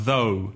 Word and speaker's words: though: Now, one thing before we begin, be careful of the spelling of though. The spelling is T-H-O-U-G-H. And be careful of the pronunciation. Though. The same vowel sound as no, go, show, slow, or the letter O.